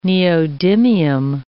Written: neodymium

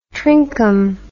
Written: trinkum

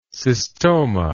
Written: cystomatous

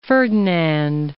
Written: Ferdinand I

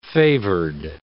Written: favored

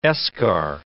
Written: eschar

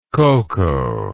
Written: koko

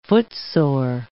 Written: footsore

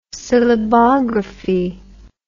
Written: syllabography

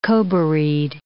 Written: Kobarid

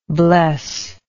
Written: blessingly

